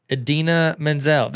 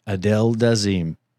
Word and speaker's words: Idina Menzel: Idina Menzel. That's the Frozen chick. – Adele Dazeem. – That's wickedly talented. – John Travolta mispronounced her name.